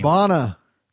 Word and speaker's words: Bana: Eric Bana? – Yeah. – What's his fucking name? – Bana.